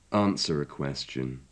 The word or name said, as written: answer a question